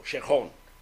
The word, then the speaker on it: Chiron: Mahitungod ining duha ka Bugatti Chiron o Chiron kung imo yung ipronounce pronounce sa iyang French name.